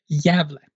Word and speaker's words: Gävle: Gävle.